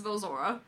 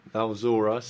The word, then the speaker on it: Valzora: It's Valzora. – Valzora.